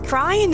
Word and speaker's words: crine: How do you spell it? – C-R-I-N-E. – That's crin. – No, it's crying. That's how you pronounce it.